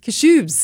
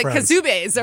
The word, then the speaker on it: Kashubes: Yeah, and I also learned how to pronounce Kashubes. – There you go. – Instead of the Kazubes or whatever I said the first time.